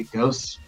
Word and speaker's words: ghost: There was no H because there is no H when you say Ghost. You know, I mean, it's ridiculous. Phonetically, it doesn't make sense.